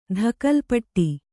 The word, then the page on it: ತಕ್ಕೋಲಪುಟ್ಟಿ: takkōlapuṭṭi